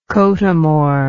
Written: cothamore

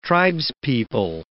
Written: tribespeople

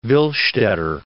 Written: Willstatter

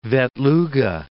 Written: Vetluga

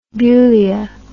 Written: bulia

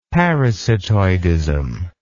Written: parasitoidism

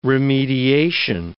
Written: remediation

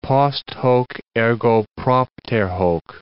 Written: post hoc, ergo propter hoc

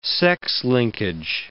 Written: sex-linkage

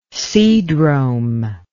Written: seadrome

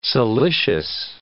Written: silicious

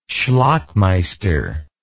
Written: schlockmeister

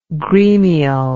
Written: gremial

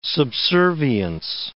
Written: subservience

